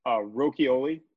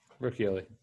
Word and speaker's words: Rochioli: Rocchioli. – Can you spell it? – And then, yeah, R-O-C-H-I-O-L-I. – I-O-L-I. – Okay, Rocchioli. – R-O-C-H-I-O-L-I.